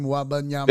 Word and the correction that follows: Wembanyama: Victor Wembanyama.